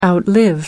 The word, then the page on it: outlive: American English